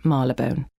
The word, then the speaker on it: Marylebone: as the locals still like to pronounce it, Marylebone.